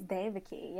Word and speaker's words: Devaki: Is it Devaki or Devaki? – Technically, it's Devaki, yeah. But who says that? No one.